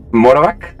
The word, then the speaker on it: Morovac: De hecho, como, eh, como mención, eh, podríamos mencionar que ah, existen unas bóvedas llamadas las bóvedas del conocimiento prohibido o las bóvedas de Morovac. ¿Sí, sí, lo dije bien? Sí. – Eh, Morovac, ajá. Que